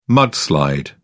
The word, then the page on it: mudslide: British English